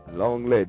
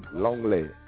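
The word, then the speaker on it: l'anglais: Long lay, do you long lay? L apostrophe A N G L A I S. Long lay. And let's get the little side note. In French, one usually does not pronounce the S at the end of words either.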